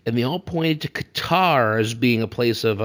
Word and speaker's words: Qatar: the, actual pronunciation seems to be up in the air, but according to a 2010 report by NPR, Mike Pesca, the pronunciation that the government of Qatar gave him was that it should sound somewhat like guitar, like Qatar, yeah. – with the emphasis on the cut and the "tar."